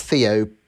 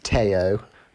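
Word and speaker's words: Théo: I think it's Theo Pourchaire, but it's probably it's pronounced Teo, like Teo Fabi, I guess, as a driver in the 90s.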